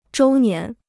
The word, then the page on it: 周年: zhōu nián